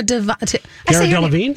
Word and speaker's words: Delevingne: So, you know, Cara Delevingne, you know, she's got those super strong but skinny, those super Cara Delevingne? – Yes. I say her name wrong all the time.